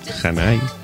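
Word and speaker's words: Zhané: Janai. Y el Saturday Night. Zane, Z-H-A-N-E. No les hacemos caso con la pronunciación. Ya dicen que es Janai.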